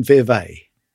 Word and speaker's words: Vevey: Nestlé in Vevey. – Yes. Yes, it rhymes.